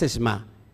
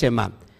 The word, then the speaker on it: Shema: Que el otro día me dijeron que no es, no es Esma, que es Shema.